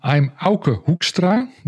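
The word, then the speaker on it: Auke Hoekstra: Okay, I'm Auka Hoekstra. That's how you pronounce it in Dutch.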